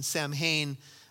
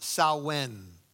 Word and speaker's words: Samhain: And so they began to celebrate a holiday called, it's spelled S-A-M-H-A-I-N, Samhain. But as you read a little bit more on this, they say it's pronounced Samhain, Samhain.